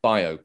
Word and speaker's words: biopic: So before we get started on the film, before we start shooting, we need to know, is it pronounced biopic or biopic? – I go biopic.